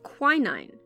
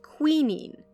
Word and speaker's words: quinine: He was studying under August Wilhelm von Hofmann, German chemist and professor, who was attempting to produce a synthetic version of quinine. If you're a British listener, you're probably used to hearing that word pronounced quinine. Rest assured, I'm talking about the same thing. I just have a funny accent.